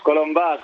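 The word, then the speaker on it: Colombàs: Colombás. Con acento en la.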